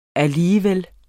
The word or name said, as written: alligevel